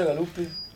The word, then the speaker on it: Galuppi: Come si pronuncia Galuppi? – Galuppi.